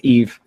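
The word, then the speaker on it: Yves: Ives Guillemot. – Is that right? – No, it's Eve. – Eve.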